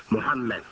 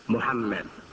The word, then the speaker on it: Muhammad: And some spell Ahmed phonetically, A-H-M-A-D. And others spell it phonetically, A-H-M-E-D, Ahmed. And really the mu in Arabic, mu sound, is not, I would say, exactly the U that we have in English. Muhammad. That's the correct pronunciation. Muhammad. Muhammad, Muhammad. So it's a little, it's a blend of the U and the O. It's actually a blend of the U and the O, Muhammad.